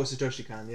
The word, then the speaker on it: Satoshi Kon: It was directed by, I don't know if I'm saying this right, but Satoshi Kon. – Where are you seeing that? Oh, Satoshi Kon, yeah.